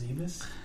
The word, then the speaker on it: Zebes: I believe it's pronounced Zebes. – I will accept Zebes.